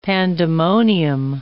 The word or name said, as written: pandemonium